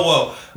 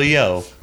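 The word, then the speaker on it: Yeoh: And the film is blatantly telling you that. And the fact that Michelle, I always get her name wrong. – Yo. – Michelle Wo. – Yo.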